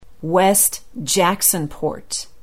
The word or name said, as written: west jacksonport